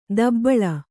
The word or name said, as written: ದಬ್ಬಳ